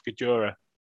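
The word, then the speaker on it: Guedioura: I thought it was Gediora. Gajora. He pronounced it himself, Gajora, two syllables.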